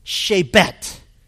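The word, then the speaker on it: shebet (שבט): The Hebrew word for rod means stick, and it's pronounced shebet. And it's easy to remember because it's the sound you make when you discipline.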